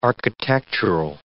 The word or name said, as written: architectural